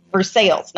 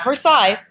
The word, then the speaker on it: Versailles: We got Versailles, Kentucky. Versailles, not Versailles, Versailles. So I understand this is a very Southern thing to butcher foreign pronunciations.